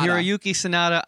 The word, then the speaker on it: Hiroyuki Sanada: Hiroyuki Sanada. – Yeah, Hiroyuki Sanada.